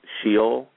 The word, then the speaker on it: Sheol: In the Old Testament, there are a couple of words that actually translate hell, which is a word pronounced Sheol.